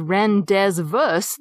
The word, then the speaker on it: rendezvous: rendezvous that you'd read in books? The rendez and the vous have a silent Z and S respectively because that's how they're pronounced in French.